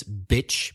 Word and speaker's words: bitch: Don't confuse it with playa, beach, B-E-A-C-H. – What's the difference in the vowel sound? – Bitch, female dog, is a short sound, bitch. And beach, playa, is the long E sound.